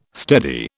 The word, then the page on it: steady: British English